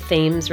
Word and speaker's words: Thames: where it was placed in a position facing the Thames River. That's how they say it in Connecticut, by the way.